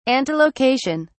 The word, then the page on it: antelocation: British English